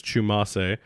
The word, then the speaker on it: Tchoumassé: So you may have heard me say that as Chu. I've learned, because I've already butchered it a couple times, that it is Tchoumassé.